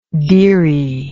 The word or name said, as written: deary